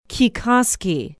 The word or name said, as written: kekoskee